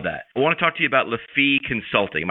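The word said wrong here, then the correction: La Fe: Am I pronouncing that right? – Not quite, but that's okay. It's Lafee. – Lafee. – And that is Spanish for faith.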